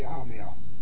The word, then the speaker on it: A-mill: It's not a big deal, but what we really say is a response to the A-mill. Give the A a long A.